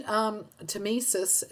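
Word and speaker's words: tmesis: And Ross, did we mention Temesis? Is that how it's pronounced? – Yes.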